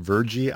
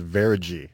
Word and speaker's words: Veregy: What the name of the company is that's doing it, like it's spelled V-E-R-E-G-Y. So it looks like Verigy. Some people call it Vergy. I talked to the guys from the company and they swear it's called Verigy.